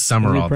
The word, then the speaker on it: Sumrall: Is that how you say it? He's new. He became part of S-U-M-R-A-L-L. – I know, it's weird. It is Summerall, though. – Is it pronounced Summerall? – Yeah. – I feel like there's a letter missing. – It is. It's not spelled right, but that's how it's pronounced.